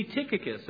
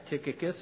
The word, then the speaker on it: Tychicus: Paul is sending this guy, Tychicus, or Tychicus, I believe it probably would be pronounced,